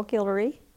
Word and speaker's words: Guillory: My name is Sherryl Guillory. Sherryl is spelled S-H-E-R-R-Y-L. Guillory is G-U-I-L-L-O-R-Y.